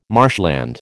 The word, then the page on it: marshland: American English